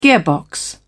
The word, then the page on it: gearbox: British English